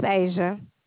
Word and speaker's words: Asia: Yes, my name is Asia. – Spell that, please. – Yes, A-S-I-A.